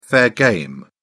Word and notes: fair game: British English

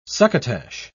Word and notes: succotash: British English

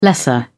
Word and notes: lesser: British English

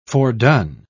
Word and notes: fordone: British English

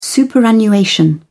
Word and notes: superannuation: British English

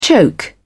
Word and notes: choke: British English

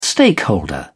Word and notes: stakeholder: British English

